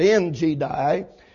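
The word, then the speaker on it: En Gedi: "Great multitude against thee, from beyond the sea on this side of Syria, and behold, there be in Hazazon Tamar and in Jedi, as the way Alexander Scorby pronounces that, in Jedi.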